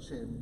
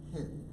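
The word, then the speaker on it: hen (חן): You know, interestingly enough, the word grace in Hebrew is the word hen. It looks like chin, but it's actually pronounced hen.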